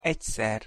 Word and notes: egyszer: Hungarian

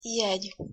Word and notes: jegy: Hungarian

